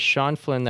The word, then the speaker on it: Sean Flynn: My name is Sean Flynn.